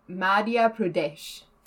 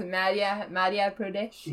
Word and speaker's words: Madhya Pradesh: Madhya Pradesh. – That's it. That's how it's said. – Yes, Madhya Pradesh.